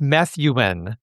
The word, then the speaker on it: Methuen: It's Methuen.